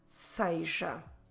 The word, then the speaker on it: seja: S-E-J-A. Seja. And I know that when I'm pronouncing it, it doesn't look like S-E-J-A, because I'm saying seja. This is one possible pronunciation closer to what it is, the standard pronunciation. If you hear people saying seja, that's just as good. But don't forget, you can say seja, but you should write it S-E-J-A.